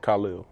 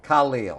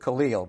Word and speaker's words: Khalil: First name, you pronounce it Khalil. – Khalil. I think back then we were going with Khalil, but he got it right.